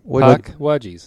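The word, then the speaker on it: Pukwudgie: Like it's a wedgie. It's the same puck. Rhymes. Puck. It's puck and wudge. – Puckwudgie. Am I still not saying it right? – Puckwudgie. – Puck. – Wudgie. There you go. Not Wudgie.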